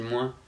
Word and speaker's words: moins: Mais euh, par exemple, je, m'aperçois que moi je disais toujours euh, moins, je prononçais le S. Et ça, je l'ai gommé en fait parce que je me faisais tout le temps reprendre. Donc maintenant, je dis moins.